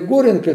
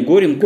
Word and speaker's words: Горенко: Вот фамилия Горенко – это Коряга, да? – Правильное ударение Горенко. – Горенко, да. – Не Горенко, да? – Нет, Горенко. Нет, Горенко, да.